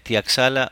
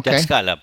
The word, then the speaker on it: Tlaxcala: Por favor, saludos a los maqueros de CITR, de CEP en Tlaxcala, México. Tlaxcala, perdón, lo dije mal.